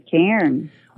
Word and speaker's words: cairn: Is it a cairn? Is that how you pronounce it? – A cairn, yes, a cairn.